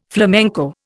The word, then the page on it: flamenco: Indian